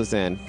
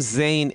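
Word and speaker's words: Zain: My brother's name is also Zane. – Really? – Zane? – It's Z-A-I-N. – Nice. – That's but it's not spelling. – It's not Zane, it's Zan. Right. It's pronounced Zan, but very, very close.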